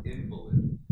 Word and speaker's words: invalid: It's pronounced invalid when it's described in person.